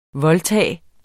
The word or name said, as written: voldtage